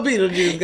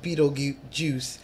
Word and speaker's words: Betelgeuse: Now, the reason why it's spelled with a J is because people will say Betelgeuse, like, you know, they do in the movie.